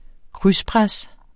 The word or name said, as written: krydspres